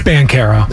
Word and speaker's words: Banchero: I don't care that Banchero, Banchero, whichever way pronunciation you want to, I don't care that he's good. – It's Banchero.